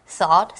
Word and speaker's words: thought: 所以在这里的 thought 其实就是想法的意思。那这个字啊，当你第一次看到，可能会有一点惊讶，因为它刚好是 think, T-H-I-N-K 的过去式跟过去分词。我们会说想 think 的三态是 think, thought, thought。而想法名词刚好也是这个拼法 thought, thought。而且它可以加 s, 是可数名词哦。 – And it's another tricky word to spell, so listen carefully. T-H-O-U-G-H-T. T-H-O-U-G-H-T. – G-H 是不发音的 Thought.